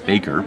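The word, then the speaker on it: Bakker: Scott Baker, that's B-A-K-K-E-R, pronounced Baker,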